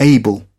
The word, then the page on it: able: British English